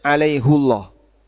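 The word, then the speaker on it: alaihullah (عليهُ الله): Jumhur qurra alaihillah. Tapi hafiz An Asim dan Quran kita hafiz An Asim, bacanya alaihullah.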